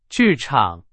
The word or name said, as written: 剧场